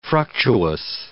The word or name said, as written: fructuous